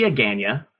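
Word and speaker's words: Hagåtña: Well, it used to be Aganya, and then there was a change to sort of make the names more. In line with the indigenous language, Chamorro. – Mm-hmm. – So now it's Hagutnya, but I'm sure I'm saying it wrong.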